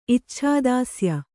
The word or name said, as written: ಇಚ್ಛಾದಾಸ್ಯ